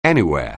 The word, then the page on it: anywhere: anglais